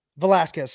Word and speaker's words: Velasquez: Not a Vasquez side. Vasquez needed some guidance. Rich Hill didn't. Like, and I think that's Velasquez. – Sorry. – That's an important middle syllable, brother.